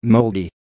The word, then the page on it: moldy: American English